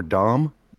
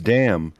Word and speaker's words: Damm: I Googled pronunciation on this one because I was curious if it was Damm or Dom. And it turns out it's Damm, but it also turns out the L's are silent.